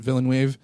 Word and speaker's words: Villeneuve: Oh, it's all French name. Yeah. It's Villeneuve. – Villeneuve.